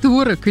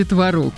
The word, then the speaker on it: творог: И я сразу почувствовала себя школьницей, которая стоит у доски и не знает, как правильно говорить, творог или творог. – А вот, ну да, уж поясняй, творог или творог, жду. – А ты знаешь, выясняется, что оба варианта в нынешнем правописании, они абсолютно равноценны.